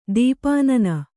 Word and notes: ತುಪ್ಪನ್ನ: kannada

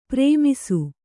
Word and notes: ಪ್ರೇಮಿಸು: Kannada; prēmisu

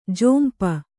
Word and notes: ಜೊಂಪ: Kannada

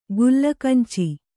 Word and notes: ಕಲಗಂಚು: Kannada

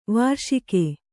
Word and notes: ವಾರ್ಷಿಕ: vārṣika; Kannada

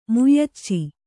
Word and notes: ಮ್ಯಾಚು: myācu

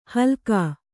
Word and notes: ಹಲಾಕು: kannada

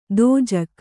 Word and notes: ದೋಜಕ್: dōjak

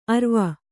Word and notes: ಅರುವೆ: kannada

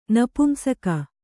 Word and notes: ನಪುಂಸಕ: napumsaka

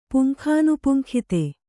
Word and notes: ಪುಂಖಾನುಪುಂಖಿತೆ: Kannada

